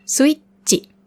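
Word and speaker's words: Switchi (スイッチ): Switchi. Auch hier wieder mit kleinem Zu, damit das Chi hart ausgesprochen wird. Das ist das Chi. Switchi.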